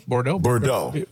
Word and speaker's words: Bordeaux: Scarlett Bordeaux, that's how you say her name? – Bordeaux. Yeah, there you go.